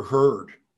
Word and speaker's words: Heard: And then I was thinking about Amber Heard. And we spell her name H-E-A-R-D. But really, it has the same pronunciation as H-E-R-D, right? Heard.